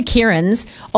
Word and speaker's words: Kieran: K-I-E-R-A-N. – A-N. So you're an A-N. And how do you pronounce that? – Kieran. – Yeah, beautiful. Okay. I can hear it. – Yep, said perfectly.